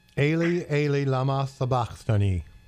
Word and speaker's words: Eli, Eli, lama sabachthani: Eli, Eli, lama sabachthani.